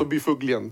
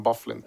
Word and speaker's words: Byfuglien: Så ska det uttalas ganska norskt. Alltså Byfuglien typ. – Byfuglien. – Och så säger de i hans hemby, men i resten av USA och Kanada så säger man Bufflin.